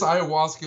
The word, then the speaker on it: ayahuasca: Do you want to talk about Iowa-asca? How do we pronounce this? – This ayahuasca isn't going to drink itself.